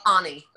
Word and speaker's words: Ani: Well, Ani, but it's okay.